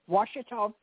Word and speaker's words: Ouachita: Ouachita. Did I say it right? Parish. – Ouachita Parish.